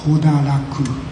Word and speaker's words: Hodaraku: I don't know Chinese pronunciation, but in Japanese we pronounce as Hodaraku, Hodaraku-san.